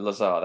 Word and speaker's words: Lazar: How did you pronounce it? Lazar, that was it.